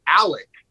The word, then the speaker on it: Alex: I also think, even though it's spelled A-L-E-X, I think he pronounces it Alec.